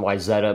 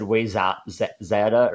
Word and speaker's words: Wayzata: Side note, I recently just learned how to pronounce the town Wayzata, Minnesota, where you do your golf tournaments. So it took me a little bit. It only took me having some buddies playing baseball against them to figure it out. I think I said Wayzata or whatever the first time. So that was a learning curve,